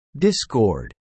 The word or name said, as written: Discord